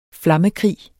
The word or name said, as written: flammekrig